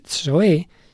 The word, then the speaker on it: zoe: Mientras que Zoe, se escribe Zoe, como de ahí viene zoológico,